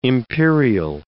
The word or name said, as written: imperial